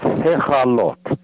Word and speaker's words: Hekhalot: And one of them is Merkava, and that's, you can spell that in English letters as M-E-R-K-A-V-A-H. And the other is Hechalot, H-E-K-H-A-L-O-T, and the K-H is pronounced as if you're clearing your throat.